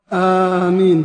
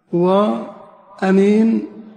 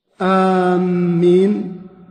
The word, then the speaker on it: آمين: لها نطقان اثنان: آمين بمد الهمزة، وأمين بقصر الهمزة، وأمين بقصرها. يقول الشاعر: تباعد مني فطحل إذ سألته أمينا فزاد الله ما بيننا بعدا، أمينا فزاد الله ما بيننا بعدا. والضبط الثاني أو النطق الثاني، النطق المشهور بالمد، آمين.